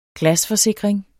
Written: glasforsikring

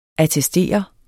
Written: ATTESTERES

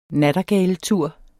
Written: nattergaletur

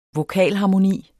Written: vokalharmoni

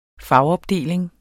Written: fagopdeling

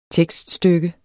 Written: tekststykke

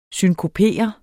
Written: synkopere

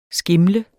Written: skimlet

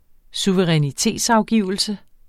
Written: suverænitetsafgivelse

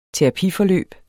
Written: terapiforløb

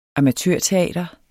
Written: amatørteater

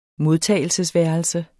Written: modtagelsesværelse

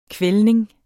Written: kvældning

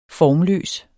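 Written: FORMLØS

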